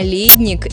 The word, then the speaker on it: ледник: А ледник – это именно глубокий погреб для хранения пищи. Кстати, ударение в этом слове только на первый слог.